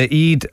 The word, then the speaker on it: Eid: And that's the Eid, E-I-D, or the Eid al-Fitr. Am I pronouncing that right? – That's right. Yeah, short and simple.